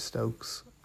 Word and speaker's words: Stokes: You can check out my YouTube if you just search for Pierce Stokes on YouTube. My name is spelled P-E-A-R-S-E, Stokes, S-T-O-K-E-S.